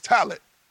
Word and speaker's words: Talaat: Well, this name of mine said it's Talaat. It's like it's Egyptian. It means to rise.